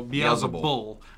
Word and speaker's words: Beelzebul: That's how I've always heard Beelzebul. – Okay, yeah. Beelzebul, not bub. – But there's no B on the end. – I don't know. That's what I've always read. Yeah. So Beelzebul, the prince of demons.